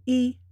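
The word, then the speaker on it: yi (一): So, first of all, when you are counting, as in 1, 2, 3, yi, er, san, it is always pronounced with a first tone, yi.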